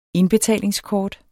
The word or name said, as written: indbetalingskort